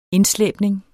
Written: indslæbning

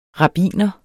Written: rabbiner

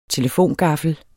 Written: telefongaffel

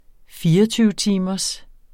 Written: 24-timers

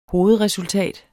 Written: hovedresultat

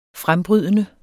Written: frembrydende

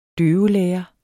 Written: døvelærer

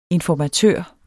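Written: INFORMATØR

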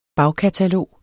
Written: bagkatalog

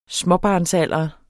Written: småbarnsalder

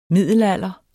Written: middelalder

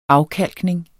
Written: afkalkning

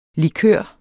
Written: likør

